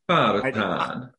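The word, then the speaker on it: Beauxbatons: Bobaton. – They do not pronounce their X's. – Bobaton.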